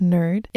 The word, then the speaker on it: NIRD: North Island Roller Derby, or NIRD, pronounced nerd, is an open gender league in the Comox Valley with around 15 skaters.